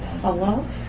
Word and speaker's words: chalav: Chalav. – Chalavimo. – The Chalavimo, right. – Now, the word chalav is pronounced, as we said it, chalav, with the vowels, two commas is underneath it, which is milk.